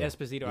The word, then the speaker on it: Esposito: Esposito.